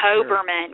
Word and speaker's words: Hoberman: I love that ball toy that you used too, the Haberman ball. Am I saying that correctly? – I think it's Hoberman, but I'm not Hoberman.